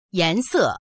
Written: yan2se4

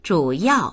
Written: zhu3yao4

